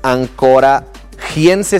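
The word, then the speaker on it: anchoragense: Porque está Ancorage, pero es Ancorachense, se escribe con G. Ancorachense.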